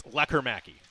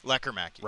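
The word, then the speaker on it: Lekkerimäki: And it's Lekker Mäki. – Lekker Mäki. – Right. Four syllables instead of five. – Lekker-Mackey.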